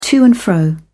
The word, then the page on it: to-and-fro: British English